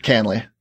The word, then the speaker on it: Kahnle: Tommy Canale. Canale? That's the ESPN guy. – Spell it. – K-A-H-N-L-E. – Canley. – Canley? – Tommy Canley on the Yankees.